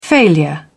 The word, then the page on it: failure: British English